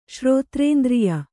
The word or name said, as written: ಶ್ರೋತ್ರೇಂದ್ರಿಯ